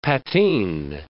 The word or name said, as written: patine